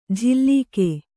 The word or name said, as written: ಝಿಲ್ಲಿಕೆ